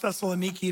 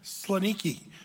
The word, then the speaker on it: Thessaloniki: The thing about Thessalonica is when Paul writes this letter, it's kind of interesting because, it's sometimes pronounced Thessaloniki if you're looking it up in old maps, or even Saloniki is another way that you'll see it.